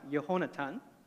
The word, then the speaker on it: Jonathan: And in this hope, like my name, Jonathan. In the Hebrew, Jonathan is pronounced Yehonatan, close to it.